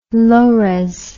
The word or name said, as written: low res